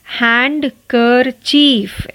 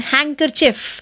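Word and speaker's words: handkerchief: हैंड कर चीफ ऐसे बोलते हैं नहीं इसका सही प्रोनाउंसिएशन हैंकर चिफ आपको चीफ भी नहीं कहना आपको चिफ कहना है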